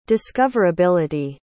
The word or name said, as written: discoverability